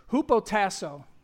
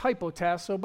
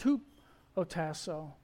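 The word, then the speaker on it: hupotasso (ὑποτάσσω): And it's hupotasso. You might say it's hypotasso, but it's not. It's pronounced hupotasso.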